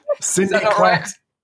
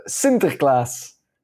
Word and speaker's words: Sinterklaas: How did you say it? Like in the Dutch version? – Sinterklaas? – Sinterklaas. – I'd love to know. Tell me. – It comes Sinterklaas. – Sinterklaas. All right, so it's not Klaus.